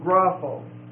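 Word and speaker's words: grapho (γράφω): So gramos comes from grapho. G-R-A-P-H-O. And it's a long O.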